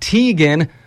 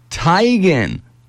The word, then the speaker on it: Teigen: The model took to social media and said it's really not Teigen, but Teigen.